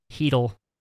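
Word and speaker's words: Chytil: Chittle, is that how you say it? – Heedle.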